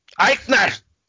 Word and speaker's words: Aichner: Marcel Barthol and Fabian Aichner? I always pronounce his last name wrong. – Aichner! – He's German.